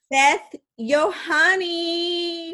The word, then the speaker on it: Yohanny: So without further ado, you guys, I am going to introduce you to our very own Anna Wintour of Phoenix, Arizona, Miss Beth Yohanny.